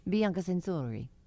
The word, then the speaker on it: Bianca Censori: released a song called Sensory Over, and I guess maybe that you say it Sensory Overload, but I assume her name is pronounced Sensory. – Mm. – Bianca Sensori.